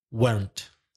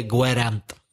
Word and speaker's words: weren't: Be careful with the pronunciation of weren't. It's one syllable. Monosyllabic, weren't. Not two syllables, one syllable, weren't. The middle E is silent. Don't say gwerant. No, say weren't.